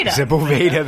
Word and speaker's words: Sepulveda: Sepulveda is pronounced Sepulveda. – Sepulveda. – Sepulveda. That's what it is.